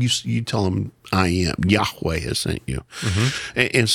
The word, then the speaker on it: Yahweh: We don't know how it's pronounced, but we pronounce Yahweh.